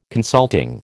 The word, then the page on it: consulting: American English